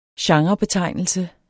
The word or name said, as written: genrebetegnelse